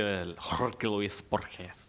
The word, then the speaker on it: Jorge Luis Borges: Skal uttales med den latinamerikanske Jorge Luis Borges.